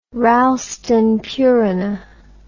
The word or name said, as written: ralston purina